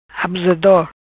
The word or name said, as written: Abusador